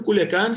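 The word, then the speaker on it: Culiacán: And you recently visited Culiacan, right? If am I saying it right? – Yeah, Culiacan, exactly.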